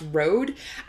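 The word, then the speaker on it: RØDE: but I think it's actually pronounced Rode. I said Rod because the logo has like this little thing through the O in the name, so which makes me think it could be Rod.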